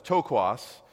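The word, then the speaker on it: tokwos (πτωχός): To really understand what poor in spirit means, you look at the Greek word used for poor in Matthew 5, which is pronounced nothing like it's spelled. It's tokwos, and it means to crouch and to cower like a beggar.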